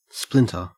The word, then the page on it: splinter: Australian English